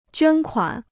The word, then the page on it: 捐款: juān kuǎn